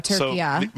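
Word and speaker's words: Türkiye: So there you go, Turkiya. – So I will be saying it Turkiya from now on. – From now on, and my entire lifetime, have been, and we have been, incorrectly pronouncing Turkey.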